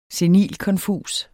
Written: senilkonfus

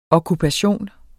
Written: okkupation

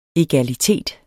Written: egalitet

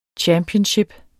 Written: championship

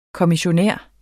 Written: kommissionær

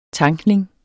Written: tankning

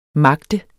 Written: magte

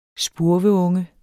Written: spurveunge

